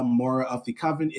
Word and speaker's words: Mora of the Coven: You can find me both under uh, Mora of the Coven. It is spelled exactly how it sounds.